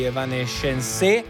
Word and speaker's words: Evanescence: 11:17 in questo istante, buona domenica, seconda ora di Good Vibrations, e parafrasando gli Evanescence, che sono tronchi notoriamente, l'accento cade l'ultima sillaba, sì, e chi seguirai?